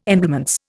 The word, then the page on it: emblements: Indian